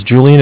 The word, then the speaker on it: Julian: Okay, my name's Julian. It's J-U-L-I-A-N.